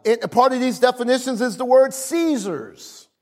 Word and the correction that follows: seizures: Am I saying that right? Seizures. Seizures. There you go.